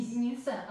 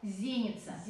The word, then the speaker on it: зеница: Не зеница, а зеница. – Зеница. – По-русски мы говорим зеница, а по-церковно-славянски зеница. Расходится ударение. Зеница.